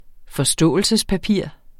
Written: forståelsespapir

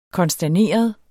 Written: konsterneret

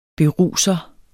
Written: BERUSERE

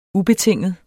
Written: ubetinget

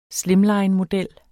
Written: slimlinemodel

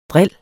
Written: dril

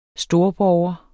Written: STORBORGERS

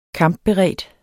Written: kampberedt

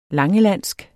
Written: LANGELANDSK

